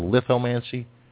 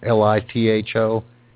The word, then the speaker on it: lithomancy: It's called Lithomancy, The Psychic Art of Reading Stones. And that's Lithomancy, pronounced like lithograph, lithomancy.